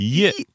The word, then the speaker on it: Ye: Är det sån man uttalar det? – Ye. – Ye. – Jag uttalar det så. – Ja. Ye.